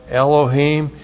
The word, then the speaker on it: Elohim: Aleph, E-L-O-H, long I-M, Elohim. Elohim with the second person plural masculine suffix, your God, a gift offering and a drink offering for the Lord your God.